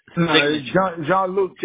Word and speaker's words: Jean-Luc: J-E-A-N-L-O, last name J-A-Y-O-T-T, and then hit submit. – Jean-Luc, J-E-A-N-L-U-C. You misspelled my name. J-E-A-N-L-U-C, and G-A-Y-O-T-T is the last name.